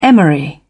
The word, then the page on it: emery: British English